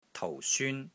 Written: TOU4 SYUN1

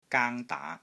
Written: GAANG1 DAA2

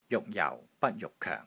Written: JUK6 JIU3 BAT1 JUK6 KOENG4

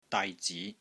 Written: DAI6 ZI2

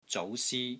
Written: ZOU2 SI1

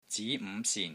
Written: ZI2 NG5 SIN3

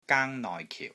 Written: GAANG1 NOI6 KIU4

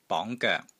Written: BONG2 GOEK3

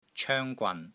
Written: COENG1 GWAN3

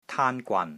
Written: TAAN1 GWAN3

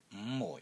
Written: NG5 MUI4

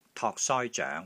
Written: TOK3 SOI1 ZOENG2

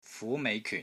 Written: FU2 MEI5 KYUN4

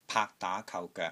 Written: PAAK3 DAA2 KAU3 GOEK3

